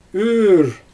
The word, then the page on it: yyr: Xyzyl